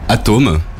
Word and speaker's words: Atoem: Et Atom, donc, qui s'écrit A-T-O-E-M, qui devrait se dire Atom normalement.